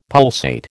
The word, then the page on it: pulsate: American English